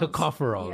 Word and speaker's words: tocopherol: To- the tocopherol. – Tocopherol, yeah. right? That's how you pronounce it.